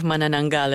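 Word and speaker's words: Manananggal: And I got to say that your pronunciation of Mananangal is quite impressive.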